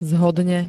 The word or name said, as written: zhodne